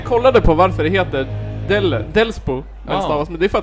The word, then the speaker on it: Delsbo: Ja, fast grundordet är Dellen. – Ja. – Ja, så då måste man uttala det Delsbo.